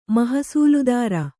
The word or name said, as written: ಮಹಸೂಲುದಾರ